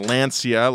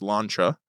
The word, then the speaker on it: Lancia: Yes, so it is not pronounced Lancia like I thought it was in sixth grade when I saw it in Gran Turismo. It is pronounced Lancia.